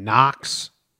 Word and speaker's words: Knox: After his favorite street in Arizona, Knox Street. So. – Knox Calhoun. – Yeah, it's a K in front of the N, so it's the alliterations there. Well, it could be pronounced Knox.